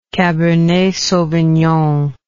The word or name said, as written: cabernet sauvignon